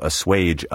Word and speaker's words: assuage: Assuage is sometimes mispronounced assuage or assuage. These recent variants have made their way into a few current dictionaries, but the traditional and proper pronunciation, countenanced by all dictionaries, is assuage.